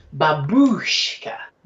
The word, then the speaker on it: babushka: It's a second syllable, that babushka.